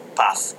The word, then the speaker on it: paz: Descansa en paz, right? Paz. Path. That's how you say it with Spanish. Path with a Z in Spanish. That's how you do it.